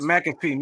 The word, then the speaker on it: McAfee: Like if you watched uh, the Pat McCaffrey, it's, that's how you say it, right? McCaffrey or mm-hmm. Mm-hmm. Uh, McCaffrey? – Mm-hmm. – McCaffrey. McCaffrey. – McCaffrey. McCaffrey. McAfee. McAfee, my bad.